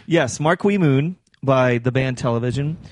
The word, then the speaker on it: Marquee Moon: We're talking about, of course, Marquee Moon. That's how you say it, right? – It is.